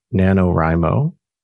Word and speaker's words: NaNoWriMo: It's the month of November, NaNoWriMo, if that's how you pronounce it. We never seem to get it right. – I think that's right. That's how I've been saying it.